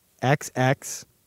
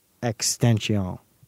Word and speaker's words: XXXTentacion: But it's the proper pronunciation for all your listeners is XXXTentacion. Extension. The first two X's stand alone. The third goes into the rest of the name. So it's XXXTentacion.